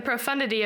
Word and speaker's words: profundity: If you've never been without, then you won't understand the profundity, is that how you say it? – Mm-hmm. – Okay, the profundity of this, how absolutely this changes your life."